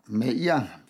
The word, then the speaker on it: Meir: Äh, das hat Golda Meir, Meir wird sie ausgesprochen,